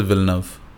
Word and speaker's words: Villeneuve: Okay, how do I say it? Villeneuve? – I think the way Americans say it is Villeneuve.